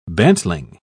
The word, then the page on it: bantling: British English